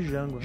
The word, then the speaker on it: Django: O D é mudo, então chama de Django.